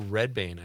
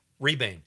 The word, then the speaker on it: Rebane: But mostly about the director, Bill Redbane. I think that's how you say it. Rebane.